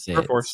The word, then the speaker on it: Perforce: Well, in some news that's kind of in at least your old area, Puppet is being acquired by, is it Perforce? Am I saying that right? – Perforce.